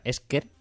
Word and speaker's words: Escher: Escher, o sea, Esker se escribe así, Escher, tal cual, ¿vale? CH.